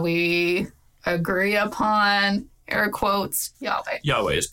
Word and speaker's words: Yahweh: Originally, we thought it was Jehovah. – Yeah, for a long time, English scholars were committed to Jehovah. – And now we think it's closer to Yahweh.